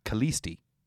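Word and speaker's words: Kallisti: is it's written in Greek. It's pronounced Kalisti.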